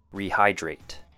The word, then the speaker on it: rehydrate: And our last one for pronunciation would be rehydrate. 3 syllables. Re has a long E sound. High sounds like the word high, as in high or low, and drate. DR sound combined with the word eight, rehydrate.